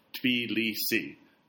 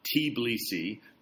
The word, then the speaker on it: Tbilisi: So the correct pronunciation is the first one I said, which was Tbilisi, not Tbilisi, not Tbilisi, as it used to say in those old radio advertisements in the 90s, not Tbilisi, as I say sometimes, and I shouldn't, and I don't know what Tiflis is.